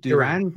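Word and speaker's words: Duren: Is it Duran? – Duran. – Yep.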